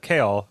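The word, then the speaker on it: Chaol: Well, I think that leads us nicely into talking about Kale, which I think is how you pronounce his name. It's like chaos, but there's no S, there's an L, it's Kale.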